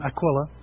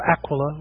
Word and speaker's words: Aquila: That's his name, Aquila, or Aquila, whichever way you want to pronounce it.